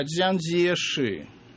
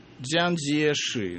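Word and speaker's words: Дзян Дзеши (蔣介石): Поэтому в общекитайском произношении имя его не Чан Кайши, а Дзян Дзеши. Дзян Дзеши.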